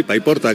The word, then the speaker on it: Paiporta: Paiporta, Alsina, ¿estás en Paiporta? ¿Lo he dicho bien? – Paiporta, ¿atención? Sí, Paiporta, Carlos.